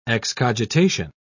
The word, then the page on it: excogitation: British English